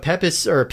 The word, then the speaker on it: Pepys: Luckily, the writer Samuel Pepys, which is spelled P-E-P-Y-S, but I looked it up, it is pronounced Pepys, who has been a member of the Brotherhood for a few years, he learns of their plans and decides to stop them.